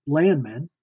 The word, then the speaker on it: Landmand: Landman, pronounced Landman, is Danish for farmer, and it's spelled L-A-N-D-M-A-N-D.